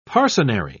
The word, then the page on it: parcenary: British English